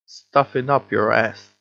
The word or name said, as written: stuff it up your ass